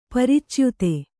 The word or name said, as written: ಪರಿಚ್ಯುತ